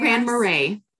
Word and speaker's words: Grand Marais: How do you say it? Grand Marais. – Grand Marais.